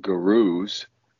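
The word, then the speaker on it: Gourus: These werewolves called gurus, not like G-U-R-U, like the people gurus, but like it's spelled G-O-U-R-U.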